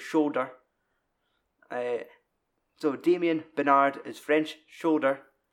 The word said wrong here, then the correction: soldier: French soldier.